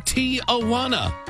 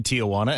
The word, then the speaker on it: Tijuana: You say Tijuana. You put an extra sound before the wana. It is a three-syllable word, which you're pronouncing as four syllables.